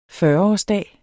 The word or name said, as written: fyrreårsdag